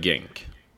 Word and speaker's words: Genk: Han, om man nu går till det laget, den stan, hur uttalas det? Man säger ju Genk, men det ska väl säkert vara så här, skänk. – Nej, men vi säger Genk.